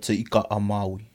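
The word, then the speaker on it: Te Ika-a-Māui: North Island, Te Ika a Maui.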